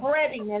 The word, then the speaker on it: Breading: It's spelled Breading, isn't it? B-R-E-A-D-I-N-G? – I think it's two E's. – Is it two E's? – No, it's E-A, like bread. – It is E-A? Okay, I wasn't sure. – Right. – Okay, so I got you. – So Breading, I'm sorry, I said it wrong.